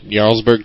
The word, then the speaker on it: Jarlsberg: Beelzebub says, why is Jarlsberg pronounced with a Y? I don't know. Jarlsberg cheese is pronounced with a Y, and that's why our Jarlsberg is, so...